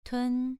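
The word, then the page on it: tun: tone 2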